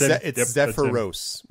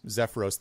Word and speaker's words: Zephyros: Sephiroth. – It's Zephyros. Zephyros. Think of Zephyr like a wind, little breeze, and then Ros. So like R-O-S.